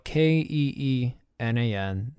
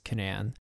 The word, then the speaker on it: Keenan: My last name is spelled K E E N A N, not Keenan, pronounced Canan.